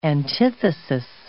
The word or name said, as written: antithesis